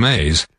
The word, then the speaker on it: Mazz: Felger and Maz 98.5 The Sports Hub Patriots ticket fugitive. – Pretty close, Carmine. It's not corn, not Maze, it's Maz. – How do you spell that for Carmine? – M-A-Z-Z.